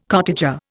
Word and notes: cottager: Indian

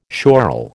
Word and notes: schorl: American English

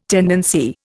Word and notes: tendency: Indian

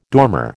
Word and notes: dormer: American English

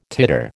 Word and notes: titer: American English

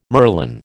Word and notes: merlon: American English

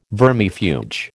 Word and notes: vermifuge: American English

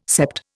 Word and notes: sept: Indian

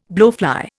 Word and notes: blowfly: Indian

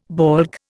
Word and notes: baulk: Indian

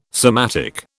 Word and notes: somatic: British English

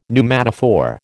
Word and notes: pneumatophore: American English

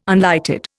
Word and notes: unlighted: Indian

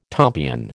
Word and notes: tompion: American English

